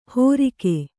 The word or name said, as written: ಹೊರಿಗೆ